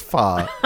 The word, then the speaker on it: pho: No, we're really going to talk about pho, which is pronounced pho.